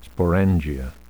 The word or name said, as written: sporangia